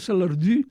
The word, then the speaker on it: Salardú: No el toco, però a la vila de Salardú, que diuen, en occità, és a dir, en aranès, sí, es pronuncia Salardú perquè hi ha la U, que és un so germànic, que també hi ha en alemany, Düsseldorf, no?